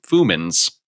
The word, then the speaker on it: fumens: If you measure light luminosity in lumens, then I guess we would measure smokiness in fumens. – Correct. – Fumens. – Correct. – Correct. It rhymes with humans.